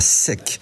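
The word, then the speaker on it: Setques: Où êtes-vous Où es-tu ce matin alors moi là je suis à Saint-Omer, ce matin j'étais à Sec et voilà, à sec, ouais. ça, ça s'écrit S-E-T-Q-U-E-S, e e mais le gag, c'est que ça s'appelle sec et que c'est inondé, voilà.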